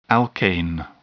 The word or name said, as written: alkane